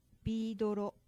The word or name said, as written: bi-doro